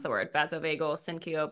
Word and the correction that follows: syncope: but whatever event. Syncope. I passed yep.